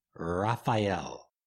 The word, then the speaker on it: Raphael: In the east is Raphael, and I'm going to use the classical pronunciation, Raphael. Raphael, visualize in beautiful yellow and purple energy, holding a caduceus or wand of healing power.